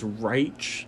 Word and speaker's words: Reich: Of course, Wentz was the quarterback under, well, I think their coach's name is Reich, is how you pronounce it, but Coach Reich was Philadelphia's offensive coordinator when Philadelphia won the Super Bowl.